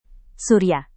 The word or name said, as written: surya